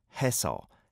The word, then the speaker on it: 했어: So when it's got a double 시옷 받침 linked to an 어 on the next syllable, 했어, 했어. So the 스 sound carries over, whereas 했어 is only a 시옷 on the second syllable.